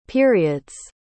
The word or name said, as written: period's